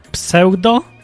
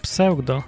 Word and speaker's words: pseudo: Pseudo? To się inaczej wymawia po angielsku. Pseudo.